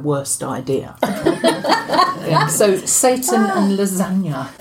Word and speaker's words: Lesanya: and I'll spell it, L-E-S-A-N-Y-A, pronounced lasagna. As in lasagna.